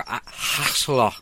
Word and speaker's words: axolotl: It's a Mexican salamander called an axolotl, or axolotl, I think. – Axolotl. – Axolotl, yeah, is the way to pronounce it, but I don't think our tongues are designed to do that thing.